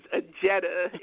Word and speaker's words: Ajita: Well, Dr. Ajita Robinson, who is, Ajita, I'm sorry, it is Ajita. It is not pronounced the way that it is spelled.